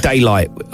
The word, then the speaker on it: Daylight: It's called Daylight, which rhymes with Marmite.